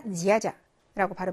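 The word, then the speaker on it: 지야자 (дядя): 아저씨, 아저씨 할때대 다음에 야가 왔죠. 그러니까 지읒처럼 발음해야 되는 거니까 지야자라고 발음하면 되고요.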